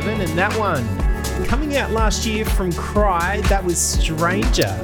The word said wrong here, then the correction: Cri: Yes, you said Cry, I've been saying Cry, but apparently it's pronounced Cree because he's French-Canadian, so it's like Cree.